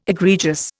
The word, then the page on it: egregious: Indian